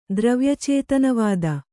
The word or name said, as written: ದ್ರವ್ಯಚೇತನವಾದ